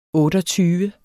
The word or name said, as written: otteogtyve